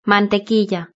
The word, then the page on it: mantequilla: Spain Spanish